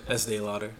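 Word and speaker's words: Estée Lauder: Estee Lauder.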